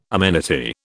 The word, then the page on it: amenity: British English